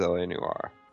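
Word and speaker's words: L.A. Noire: I was like, is it L.A. Noir? It is L.A. Noir.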